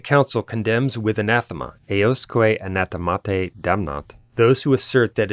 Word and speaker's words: anathemate: By the way, in ecclesiastical Latin, the th consonant group is pronounced simply as a t. For example, it's theologia rather than theologia. That's why I pronounced the ablative form of the Latin word anathema as anathemate, in case anyone was wondering.